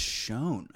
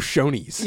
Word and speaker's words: Chão: That's how you say it, right? – Yeah. – I think it's Shone.